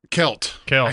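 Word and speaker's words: Celt: Selt. – Kelt. – I think you actually got it right.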